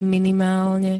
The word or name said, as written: minimálne